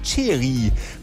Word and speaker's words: Xeri: Das Hoch Cheri meldet sich an. Mit X geschrieben, lustigerweise. – Cheri? – Ja, genau, X-E-R-I geschrieben. Spricht man ganz anders aus.